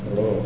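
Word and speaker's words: Rho: And that would be like the, it's pronounced like if you were pronouncing in her, the word her, the H and the R together. Row.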